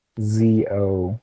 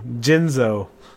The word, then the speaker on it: Jinzo: Oh, it's not Jizno. It's Jizno is what your lady says to you. – Yeah. Jinzo. That's how you say it. – Yeah. – I'm Big Jinzo. Not Jizno.